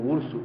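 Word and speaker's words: ours: como urso, urs.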